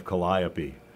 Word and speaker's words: Calliope: I have to commend you, Brian, for your pronunciation of Calliope.